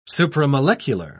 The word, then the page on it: supramolecular: British English